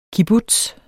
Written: kibbutz

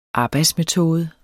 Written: arbejdsmetode

